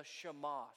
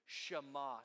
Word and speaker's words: Shema: It's a passage known as the Shema. Say that with me. Shema. Say it like you mean it. Shema. S-H-E-M-A.